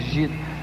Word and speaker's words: Gita: Muita gente me pergunta o que é essa música Gita, e é se é Gita, se é Gita. Gita é, é a pronúncia porque é, vem de um livro indiano chamado Bhagavad Gita.